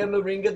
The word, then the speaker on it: Moringa: And so I started looking up that the the product that you're taking, and it's called Moringa. Is that correct? Is that how it's pronounced? Yeah, Moringa. There's there's various products that we use, but obviously we don't run rounds.